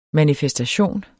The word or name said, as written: manifestation